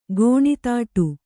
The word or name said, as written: ಕಣ್ತಡೆ